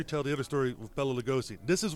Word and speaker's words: Bela Lugosi: Bela Lugosi.